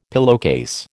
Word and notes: pillowcase: American English

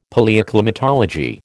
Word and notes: Palaeoclimatology: American English

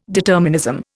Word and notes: determinism: Indian

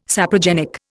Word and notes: saprogenic: Indian

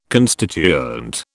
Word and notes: constituent: British English

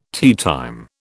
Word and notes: teatime: British English

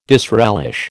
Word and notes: disrelish: American English

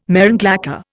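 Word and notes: marron glacé: Indian

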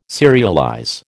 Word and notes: serialize: American English

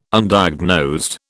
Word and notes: Undiagnosed: British English

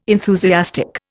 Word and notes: enthusiastic: Indian

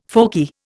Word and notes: folky: Indian

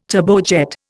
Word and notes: turbojet: Indian

